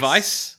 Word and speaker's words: Weisse: Weiss. – Weiss. – Weiss. – Weiss. Weiss, like the German Weiss.